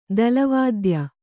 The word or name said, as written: ತಾಳವಾದ್ಯ